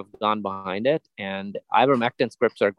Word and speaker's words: Ivermectin: Ivermectin, am I pronouncing that right? – Yes.